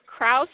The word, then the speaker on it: Kraus: It is Kraus like mouse.